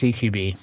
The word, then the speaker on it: CQB: How do you say the CQB one? I'm always confused. – It is service CQB.